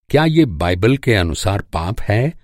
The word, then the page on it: अनुसार: انوسار